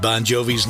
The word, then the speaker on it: Bon Jovi: Won't you take me to pronounced Bon Jovi, but it's spelled B-O-N-G-I-O-V-I. Phonetics in America, the Italian G-I is a J sound, made it easier to remember.